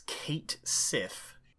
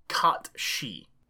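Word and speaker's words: Cait Sith: It's written as Kate Sif, but it is pronounced Kat Shi.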